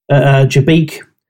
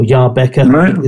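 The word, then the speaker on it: Jabbeke: I've been wanting to go to it for a few years, called On the Road, Jabeek, or Jaarbeke, is how the Belgians pronounce it.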